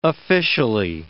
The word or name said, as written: officially